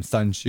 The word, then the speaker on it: Sun Tzu: Sun Tzu, yes.